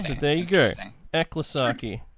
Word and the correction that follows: Ecclesiarchy: I know it is ecclesiarchy, but Eklasaki.